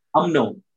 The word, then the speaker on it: UMNO: What about, because that first, the party, the first party that the UN, UNMO. I know you, UNMO, is that how you say it? – UMNO.